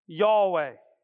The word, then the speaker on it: Yahweh: So when God says his name, right, he says Yahweh. And that's how we pronounce that.